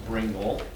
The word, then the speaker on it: Bringle: Sometime, I'd suggest a book to you by a woman, a theologian, Mary Louise Bringle, rhymes with Pringle.